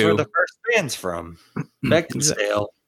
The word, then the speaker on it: Beckinsale: And just side note, you keep pronouncing Kate's last name as if there's a D in it. – Mm-hmm. What is it? – It's Beckinsale.